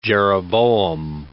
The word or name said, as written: jeroboam